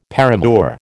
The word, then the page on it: paramour: American English